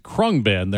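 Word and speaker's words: Khruangbin: If you want to jot this down, it's K-H-R-U-A-N-G-B-I-N, pronounced Krungbin.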